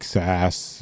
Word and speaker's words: SaaS: So, and, you know, so there's all these terms like SaaS and PaaS and okay, what are these? Am I pronouncing them correctly? – Yes, first? – I think I've never.